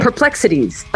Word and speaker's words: Perplex Cities: I'm like, because it's the word perplexity, but I made it into cities, so you have to stop, and that's awkward. – Anyway, perplexities. Okay, now I'm saying it right.